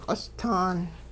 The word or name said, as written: q'ostaan